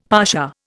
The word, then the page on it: pasha: Indian